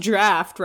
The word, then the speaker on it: draught: Oh, also, last week on last week's episode, I pronounced the word draught wrong. DRAUGHT. I pronounced it draught, and then Tyler texted me the next morning and was like, you know, it's pronounced draft, right?